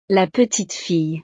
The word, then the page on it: la petite fille: frances